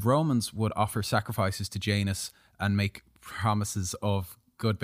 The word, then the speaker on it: Janus: This new date honored Janus, like anus with a J, a two-faced god.